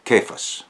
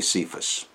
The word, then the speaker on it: Cephas: You shall be called Cephas, or you might say Cephas. I like to use the Aramaic pronunciation.